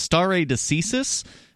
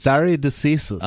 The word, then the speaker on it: stare decisis: He pronounced it, and I did look it up. It's stare decisis. It's stare decisis. – Latin, and in Latin it means let the decision stand.